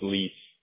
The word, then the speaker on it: Lies: And that's your last name, L-I-E-S, right? – Yeah, it's pronounced Lease.